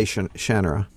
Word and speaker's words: Shannara: You know, I say Shannara.